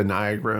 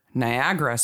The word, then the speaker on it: Niagara: And then the word Niagara spelled out in about 25 point font, because as long as I have lived, I didn't know that there was an extra syllable in Niagara.